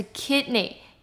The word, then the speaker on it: kidney: 他不小心呢吞下了口香糖。有一个字呢跟他发音有一点像，它是 swollen, swollen, 它的 S-W-O 这边是发 wa 的音， swollen, swollen, 是肿胀的意思。那我们可以说 his ankle is swollen from the fall. 他因为摔倒让他的这个脚踝呢肿胀。再我们要来看的是 kidney， kidney 呢是肾脏的意思，重音在 kid 的地方发短音 kid，